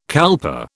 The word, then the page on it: kalpa: British English